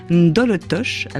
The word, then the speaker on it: Ndolotosh: Aussi dans la composition de ces cuisines, on est végétarien, on est vegan surtout, on s'approche d'un concept que vous avez appelé Ndolotosh. Alors, est-ce que je le prononce correctement déjà ? – Tout à fait.